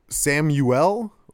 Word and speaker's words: Samuel: Like, S-A-M-U-E-L, right? I know how I say it. I know how my family says it. But there's always someone who's like, do you prefer Samuel or Samuel?